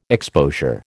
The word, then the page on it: exposure: American English